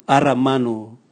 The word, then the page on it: ara manu: Raga